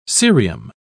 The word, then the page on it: cerium: British English